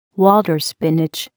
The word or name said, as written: water spinach